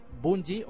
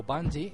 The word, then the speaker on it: Bungie: Bueno, pues hablamos de que Bungie o Bungie, según pronunciéis el, idioma, de los ingleses guiris, que es, ¿cómo se llama?